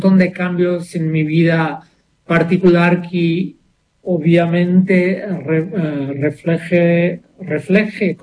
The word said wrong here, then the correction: reflejé: ¿cómo se dice? ¿Está bien? Refleje, ¿sí? – Reflejé.